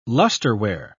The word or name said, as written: lusterware